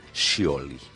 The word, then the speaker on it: Scioli: Como son las fotos del candidato oficialesco Daniel Scioli. Tal y como ¿qué? Se pronuncia en italiano. Scioli. – Sí, pero nadie le dice Scioli.